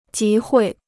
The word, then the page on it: 集会: jí huì